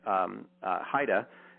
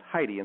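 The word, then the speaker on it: Heide: And her last name is it's H-E-I-D-E, which looks like Haida. It's supposedly supposed to be pronounced Haida, but in this case, it's pronounced Heidi.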